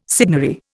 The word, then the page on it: signory: Indian